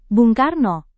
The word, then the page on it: bung karno: Indonesian